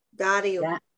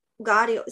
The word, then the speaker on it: Karhiio: Karhiio? – Yeah. Karhiio. – Karhiio. – Yeah. The K is a G sound. Karhiio. Yeah. Okay. Comes from the back. – Karhiio.